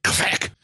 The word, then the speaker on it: QFEC: Best pronounced with a bit of an Arabic accent. Qfec.